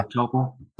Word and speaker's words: Melitopol: Well, yeah, there, there's kind of driving south through Zaporizhia, going through uh, how do you pronounce it, Melitopol, uh-huh. Melitopol.